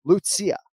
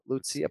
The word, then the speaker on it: Lucia: but it's Lucia. It's 3 syllables with the first name. So Lucia Pudilova.